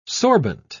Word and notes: sorbent: British English

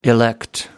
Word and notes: elect: American English

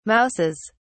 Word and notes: mouses: British English

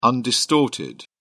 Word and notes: undistorted: British English

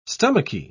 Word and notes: stomachy: British English

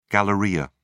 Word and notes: galleria: British English

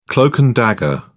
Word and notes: cloak-and-dagger: British English

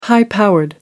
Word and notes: high powered: American English

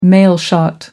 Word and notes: mailshot: American English

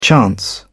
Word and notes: chance: British English

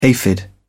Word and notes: aphid: British English

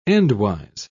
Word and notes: endwise: British English

